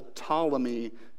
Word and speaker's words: Ptolemy: So we have this dynasty in the south, the kings of the south in Egypt, called the Ptolemy dynasty. Okay, the Ptolemy, that's spelled really strangely, Ptolemy.